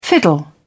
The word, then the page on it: fiddle: British English